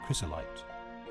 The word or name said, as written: chrysolite